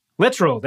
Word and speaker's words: Littrell: Littrell.